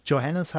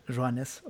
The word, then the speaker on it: Joannes: Also gerne jeder mal einfach Highlights von Marine Joannes, die nicht Johannes heißt, sondern tatsächlich im Französischen, ist wahrscheinlich auch das einzige Wort, was ich beherrsche, Joannes ausgesprochen wird.